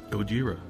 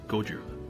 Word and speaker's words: Gojira: Gojira, which I believe is the legal pronunciation of the band name. If you say Gojira, then the Toho people come knocking, and we don't want that.